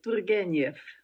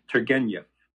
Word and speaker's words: Turgenev: Russian names are not the most easy to pronounce, but it's Turgenev. – Okay, Turgenev. I totally botched it.